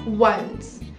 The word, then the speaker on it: once: E eu quero chamar a atenção na palavra once. Então, é, você pode pronunciar bem esse O com N, ficando once upon a time.